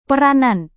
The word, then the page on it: peranan: indonesian